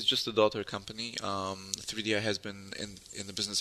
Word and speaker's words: 3DI: It's called 3DI, but is it Third Eye? Is that how you pronounce it? – No, it's pronounced 3DI.